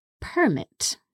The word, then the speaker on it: permit: For example, the verb permit, which means to allow someone to do something. As a noun, that would be pronounced permit.